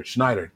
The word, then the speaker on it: Schneider: So we know why he was there, but they did Drake Jackson, special teams coordinator, Brian, I always say his last name wrong. Schneider? Yeah. Schneider. Damn it.